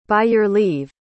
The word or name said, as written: by-your-leave